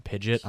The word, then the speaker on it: Pidgeot: There was this website that showed how to pronounce all the Pokemon names, and they pronounced Pidgeot Pidgeot on that website. So I was like, as a kid, I was like, it's Pidgeot, guys. Pidgeot. Anyone who pronounces it differently is wrong. – Pidgeot. – Because this website says it's pronounced Pidgeot.